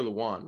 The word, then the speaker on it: Lewan: It's Taylor Lewan, right? Yeah. That's how you say it? Yeah.